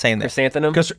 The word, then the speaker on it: Chrysanthemum: Chrysanthemum. You said it right.